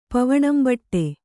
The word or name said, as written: ಪವಣಂಬಟ್ಟೆ